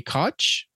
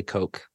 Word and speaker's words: Koch: It's actually Koch. Sorry. – Oh, was it really? – Yeah, that's a common last name. I wouldn't say common, but it's one that you see a lot in politics. So I'm fairly certain it's pronounced Koch.